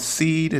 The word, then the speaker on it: Cede: A couple of games specifically that I enjoyed was a game called Seed. It's spelled C-E-D-E.